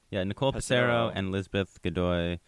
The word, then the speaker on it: Godoy: I'm going to totally butcher this last name, but it's spelled G-O-D-O-Y Godoy. Godoy. Lizbeth Godoy. – Shout out to like good day.